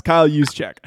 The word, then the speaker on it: Kyle Juszczyk: It's Kyle Juszczyk.